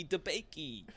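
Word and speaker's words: DeBakey: And then the last name rhymes with DaBaby, like Roddy Ricch. It's Michael E. DaBakey.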